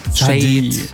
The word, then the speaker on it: Sade: Gleich hören Sie eine Band, da kursieren wirklich die wildesten Aussprachen des Bandnamens. Geschrieben werden sie S-A-D-E. Und das hören Sie da immer so, Sade, Sade. Das wäre tatsächlich richtig, Sade.